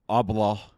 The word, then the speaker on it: Abloh: Is it Ablah? – Ablah.